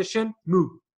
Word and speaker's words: Shenmue: e a pronúncia correta é Shenmue.